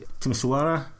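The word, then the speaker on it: Timișoara: Timisoara, I think is the pronunciation, in Romania, the same weekend.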